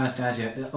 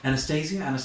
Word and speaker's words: Anastasia: So, because I have my understanding of Anastasia only is. – Oh God, did I say it wrong? – Yeah. Anastasia.